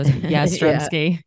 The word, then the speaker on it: Yastrzemski: So Yastrzemski.